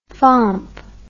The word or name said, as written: fomp